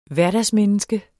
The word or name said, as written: hverdagsmenneske